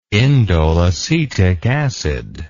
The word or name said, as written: indoleacetic acid